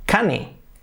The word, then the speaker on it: cane: Cane, pues vamos a pensar nombre, name y también avión, plane. Sabemos cómo se pronuncia avión, plane, que es plane. Sabemos cómo se dice nombre, name, que es name. Por lo tanto, cane será cane.